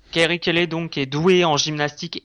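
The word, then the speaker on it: Carrie Kelley: Donc c'est Kerry Kelly, je sais pas si je le prononce bien. Oui, Kerry Kelly, oui.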